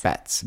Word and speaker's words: Batz: Bats. B-A-T-Z. – Oh, my God. I would have pronounced that Bots.